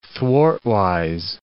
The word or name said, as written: thwartwise